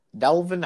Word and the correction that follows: Devlin: Devlin Hodges. Devlin Hodges, not Delvin.